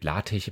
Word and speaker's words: LaTeX: Es gibt LaTeX immer noch mein Lieblings, weil es wird ja am Ende mit X geschrieben, aber es soll ja nicht LaTeX ausgesprochen werden, der Doppeldeutigkeit wegen.